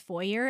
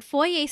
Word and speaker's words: foyer: Americans pronounce foyer as foyer, and that pisses me off. – Yes. It's foyer. Foyer. – No, yeah, it's a French word. – You're French. – It's a foyer. – Yeah, you're a French fuck. – It's a French word, so it has to be pronounced French.